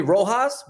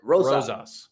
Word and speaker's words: Rosas: My football coaches will call me Rojas, but you nailed it. Rosas.